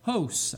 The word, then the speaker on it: hos: but it's H-O-S with a long O, so hos, I think it's pronounced hos, the Greek word, but it means in that manner, okay, in that manner.